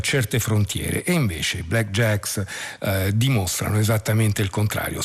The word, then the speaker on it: BLKJKS: Si scrive BLKJKS e come è un po' moda negli ultimi anni si eliminano eh, le vocali, ma insomma mm, va letto in questo modo.